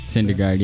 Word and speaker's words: Syndergaard: Syndergaard, yeah.